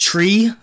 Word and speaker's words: tri: Well, it's actually probably pronounced tree, but it's T R I. Childeberg. – T R I. – Which actually could double. Welsh is also T-R-I, but it's probably a different pronunciation.